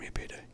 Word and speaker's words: Millipede: B-U-R-N-T. – Oh, thank Burnt. – God. – Mia Payday. – Okay, Burnt Mia Payday. – Which is spelled millipede, but pronounced Mia Payday.